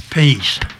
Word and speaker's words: Pease: Peace. – Peace. – P-E-A-S-E. – Peace. – Please, without the L.